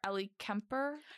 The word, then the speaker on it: Ellie Kemper: However, I'm pretty sure Ellie Kemper, that's how you pronounce her name, right? – Yes.